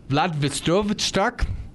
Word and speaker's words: Vladivostok: So now there is a combination of the Russia Vladivostok.